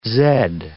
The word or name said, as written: zed